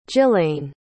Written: jillayne

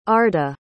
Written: arda

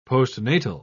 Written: postnatal

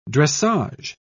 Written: dressage

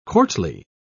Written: courtly

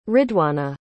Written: ridwana

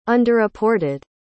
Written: underreported

